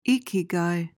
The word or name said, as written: ikigai